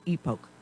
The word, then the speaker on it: Epoch: And my point being is that I subscribe to the Epoch Times, or as people pronounce the name correctly in Greek, Epoch, E-P-O-C-H, the Epoch Times.